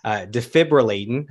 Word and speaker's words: Defibrillatin: Okay, so the first album you released in 2000, this is Defibrillatin. Am I saying that correctly? – You got it.